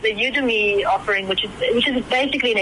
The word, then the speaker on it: Udemy: One of FNB's eBucks partners is Udemy. Is that how it's pronounced? Udemy. – Udemy, that's correct.